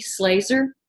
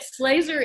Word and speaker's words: Slazer: Slazer is a very, and it's spelled just like it sounds, S-L-A-Z-E-R.